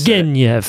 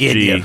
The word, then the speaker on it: Turgenev: That's how I've always Turgenev. – Said it. – Not the soft Turgenev. G, but it's hard.